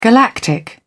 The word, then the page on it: galactic: British English